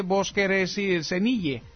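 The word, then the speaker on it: Voskresenye (воскресенье): Voskresenye.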